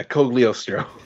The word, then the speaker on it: Cogliostro: Yeah, Cogliostro.